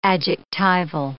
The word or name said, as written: adjectival